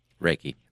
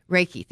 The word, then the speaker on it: Reiki: Is it Reiki or Reiki? – Reiki. – Reiki. Thank yep.